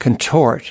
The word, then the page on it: contort: American English